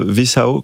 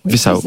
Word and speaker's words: Visão: Vous avez choisi l'hebdomadaire portugais Visão, que je prononce très mal. – Visão. – Parfait.